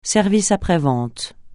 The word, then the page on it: service après-vente: francais